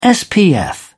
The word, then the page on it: spf: British English